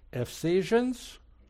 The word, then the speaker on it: Ephesians: Ephesians? How'd I do? Close? No cigar? Okay. How do you say it? Okay, you got it.